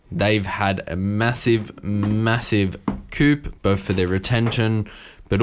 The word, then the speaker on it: coup: I think, but I don't think the P is pronounced. – Okay, well, massive coup.